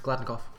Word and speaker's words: Gladnikoff: Are you sure you're pronouncing the surname right? – Yes, I've asked them. It's Gladnikoff. The inflections in the A and the N. I've asked.